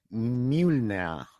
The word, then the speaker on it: Mjölnir: Oye, eres el gerente dueño, es sueco el nombre, lo digo por exhibir mis dotes de sueco, Box Mjölnir. – Mjölnir, CrossFit Mjölnir. – Bueno, sueco, número 2, sí, por ahí. – Vale, es que si es sueco, el final nir se pronuncia como na, te lo digo por eso.